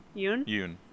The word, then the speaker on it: Yeun: Like that one and Steven, how do you pronounce his last name? Steven Yeun? – Yeun. Yeah.